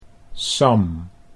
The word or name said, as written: Sam